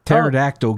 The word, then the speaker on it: pterodactyl: You hear a pterodactyl going to the bathroom? Because the P is silent.